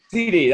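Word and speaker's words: CeeDee: Yep, C.C. Lamb. – C.C. Lamb. – Is it C.D. or C.C.? – C.C. – C.D., that's right. Gosh. – And I think it's spelled out, too. – Yeah, it's huh? C-E-E-D-E.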